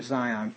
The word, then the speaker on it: Zion: And I looked, and lo, a lamb stood on the Mount Zion, I think the S is pronounced as a Z, I'm pretty sure, Zion.